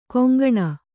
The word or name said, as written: ಕಂಕಣ